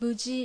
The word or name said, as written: Buji